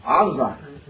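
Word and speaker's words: Azza (עזה): How do you say Gaza in Hebrew? Azza. Azza with the ayin, Azza.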